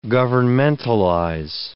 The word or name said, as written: governmentalize